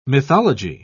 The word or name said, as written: mythology